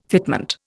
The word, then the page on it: fitment: Indian